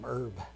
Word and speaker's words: herb: For the Lord God, I knew a guy named Herb, and I used to call him Herb. I couldn't get that straight, but it's Herb, silent H.